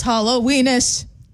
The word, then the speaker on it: Halloweenus: It's Halloweenus. – It rhymes with penis.